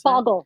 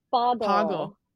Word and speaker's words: pagal: There's also, I hope I'm pronouncing this correctly, she's kind of labeled the Pagal. Is that how you pronounce Poggle. – It? Poggle. Okay.